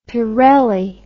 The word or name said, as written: pirelli